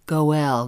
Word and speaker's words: goel: The meaning behind the Hebrew word goel, that's spelled G-O-E-L.